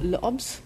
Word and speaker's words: L'Obs: He's now a weekly columnist for L'Obs, which I'm probably mispronouncing. – No, perfect. No, fine.